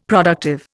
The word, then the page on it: productive: Indian